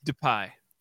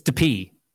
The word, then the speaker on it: DPi: I believe it's pronounced DePie. – It's DePie.